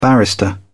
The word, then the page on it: barrister: British English